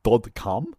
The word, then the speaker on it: Dod Kalm: We are talking about this week and Dod Kalm. It's how you would say it and pronounce it in the Norwegian. It's the Norwegian for dead calm, I'm assuming.